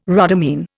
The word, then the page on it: rhodamine: Indian